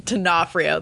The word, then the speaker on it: D'Onofrio: D'Onofrio, that's how you say it.